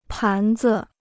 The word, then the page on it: 盘子: pán zi